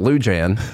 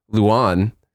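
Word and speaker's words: Lujan: It must be like her old last name or something that she wants to use because it has a silent J in it. And so they want to make sure, not silent J, it's a, you know, what do you call it? – A hard J? – Well, it's not a, her name isn't Lou Jan. I'm sure they say Luan.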